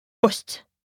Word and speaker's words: ost: That is, ost.